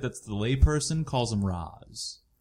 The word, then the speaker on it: Ra's: But they talked about how they had to justify it because in the comic books they pronounce it Raish, but then all those Christopher Nolan movies kept saying Ra's, so now they had to find a way to meet in the middle.